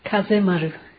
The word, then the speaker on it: Kazemaru: Kazamaru? What a nice name. How do you pronounce it properly? – Kazemaru. – Kazemaru.